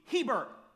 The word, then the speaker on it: Heber: Now, when you read that word Eber there, I pronounced it wrong. It should be Heber.